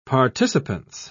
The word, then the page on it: participance: British English